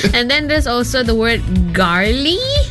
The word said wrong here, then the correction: gnarly: Gnarly. – Gnarly. – Yeah, the G is silent.